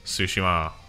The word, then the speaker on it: Tsushima: Well, I should know, because I took Japanese for a bit, so... Tsushima. There.